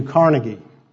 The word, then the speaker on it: Carnegie: Andrew Carnegie made a fabulous fortune. I know you hear his name pronounced Carnegie now, and I guess that's a correction of the old pronunciation, but I'm stuck with the old pronunciation.